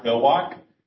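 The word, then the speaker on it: Villwock: My name is Mark Vilwak. And it's spelled M-A-R-K, and last name, V as in Victor, I-L-L-W-O-C-K.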